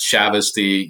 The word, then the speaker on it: Chavoustie: It's Chavesty. You nailed it. It's so hard to do, and you got it right.